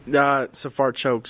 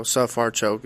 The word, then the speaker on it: Safarchuk: Safarchuk. So it's kind of like S-U-H, Sa, then Far, F-A-R, and then Choke. So Safarchuk is the most simple way for me to put it like that.